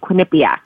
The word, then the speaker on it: Quinnipiac: And so just out of curiosity, how do you pronounce the university where you're receiving your MBA? – It's actually in Connecticut. And it's called Quinnipiac. So you were pretty close. – Quinnipiac.